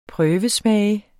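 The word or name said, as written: PRØVESMAGE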